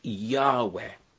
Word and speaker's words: Yahweh: You've got to put in the vowels that go between those consonants, and when you do, you've got a word that's pronounced Yahweh.